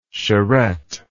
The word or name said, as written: charrette